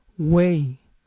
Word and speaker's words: weigh: Noten que peso es weight con una T final, mientras que el verbo pesar es weigh sin T final, además de que no se pronuncia la GH final.